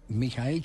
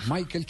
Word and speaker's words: Michael: que es donde está recluido Mijael Schumacher así, es la pronunciación eh, original en alemán. – Ah, Michael Schumacher, eh, eh, se dice en inglés.